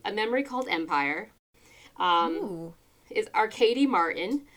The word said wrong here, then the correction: Martine: Um, Martine, I think it actually might be Martine. I might have mispronounced that.